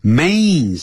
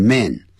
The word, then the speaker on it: main: main 是主要的 main, 不要念成 man, main idea, 叫 main idea, a pain, painful, 不要念成 painful, 所以每一个发音呢，这个 a 加 n 的音的时候，一定要念成 a pain pain in my chest when I breathe.